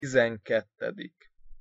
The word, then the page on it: tizenkettedik: Hungarian